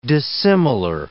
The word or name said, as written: dissimilar